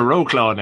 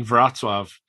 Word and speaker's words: Wrocław: And I was like, oh, you mean Wrocław? It's like, yeah, I've been pronouncing that completely wrong because W's or V's and an L with a line through it.